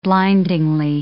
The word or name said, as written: blindingly